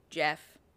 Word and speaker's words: Gef: He said his name was Jeff, spelled G-E-F, pronounced Jeff, and he was, quote, an extra, extra clever mongoose, end quote, born in India in 1852, so he's 83.